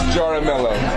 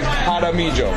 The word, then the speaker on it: Jaramillo: Ronny Jaramillo. – Jaramillo? – Yes. – Spell that for us. – Jaramillo. – Ronny? – Yeah. Well, you want Americanized? It's Jaramillo. The real juicy stuff is Jaramillo.